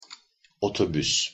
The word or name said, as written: otobus